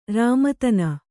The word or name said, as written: ರಾಮತನ